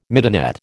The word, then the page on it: midinette: American English